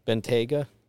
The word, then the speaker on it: Bentayga: Bentayga.